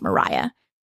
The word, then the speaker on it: Maria: And if you want to look her up, her name is actually spelled like Maria, but it's pronounced Mariah.